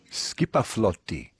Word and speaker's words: skipaflotti: Skipaflotti is how they say it for a fleet. A Viking craft.